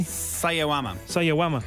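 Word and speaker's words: Sawayama: Marina Sayayama, is that how I'm pronouncing that correctly? – Sayawama.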